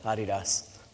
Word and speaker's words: Adidas: I appreciate that you pronounce Adidas the right way, Adidas. That's right, founded by Adi Dassler.